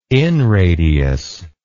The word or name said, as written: inradius